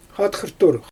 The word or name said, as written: xatxajrtur